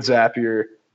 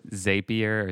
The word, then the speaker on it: Zapier: I think there's definitely two different camps of pronunciation on that software, but I go with Zapier. that software. – I think their slogan is Zapier makes you happier.